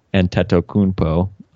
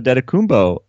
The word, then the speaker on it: Antetokounmpo: Gus Johnson started pronouncing it Antetokounmpo on Wednesday night, which was interesting because he's always been an Antetokounmpo guy.